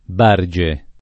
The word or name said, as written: Barge